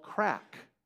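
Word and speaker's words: craic: It's called crack. And if you go to Ireland, you will even be able to go around in a small town and go, which pub here has good crack? Now, not C-R-A-C-K. You might have to spell it for them. It's C-R-A-I-C, spelled the way a good Irishman would spell it, right?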